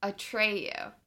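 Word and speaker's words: Atreyu: I forgot that they sent for our trait it's not I always said our Treyu. It's a Treyu. – It's not our Treyu. – It's, it's your Treyu. A Treyu. Yeah, I was saying his name wrong, but from the Plains People, Hunters of the Purple Buffalo.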